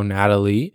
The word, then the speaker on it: Nathalie: The Yogi lawyer, Yogi, after attorney, this is the name, Natalie, spelled with an H after the T, Nathalie, possibly it is also still pronounced as, previously had, said a couple of seconds ago, Natalie.